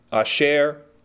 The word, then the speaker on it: Asher: The Hebrew says, "Eyeh Asher Eyeh." Eyeh is spelled E-H-Y-E-H, Eyeh. Asher, same as Asher, but it doesn't mean happy. It's just pronounced differently. Asher, A-S-H-E-R, and then Eyeh again.